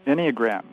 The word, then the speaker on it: Enneagram: Did I pronounce that word correctly? – Enneagram, that's right.